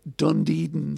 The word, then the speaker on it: Dunedin: The Dunedin sound. Dunedin sound. Dunedin sound. There we go. So there was a band formed in 1978 in Dunedin. D-U-N-E-D-I-N. There we go. There's a silent D that's very visible, if that makes sense.